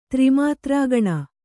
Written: ತ್ರಿಮಾತ್ರಾಗಣ

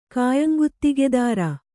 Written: ಕಾಯಂಗುತ್ತಿಗೆದಾರ

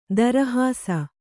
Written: ತಿರುಹಿಸು

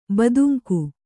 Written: ಬೆದಂಕು

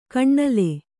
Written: ಕೈನಿಲ್ಲು

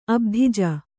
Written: ಅಬ್ಧಿಜ

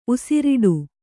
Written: ಉಸಿರಿಡು

